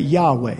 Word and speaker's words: Yahweh: Later, that gets shortened to just I am Yahweh, and it just in English, or we usually pronounce that Yahweh.